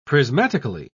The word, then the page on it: prismatically: British English